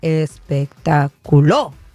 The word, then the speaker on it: espectáculo: Espectáculo. ¿Espectáculo? – No, en la U no. Le estás poniendo el acento en la U. – A ver, espectáculo.